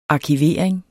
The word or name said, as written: arkivering